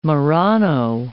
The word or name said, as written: Marrano